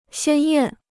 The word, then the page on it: 鲜艳: xiān yàn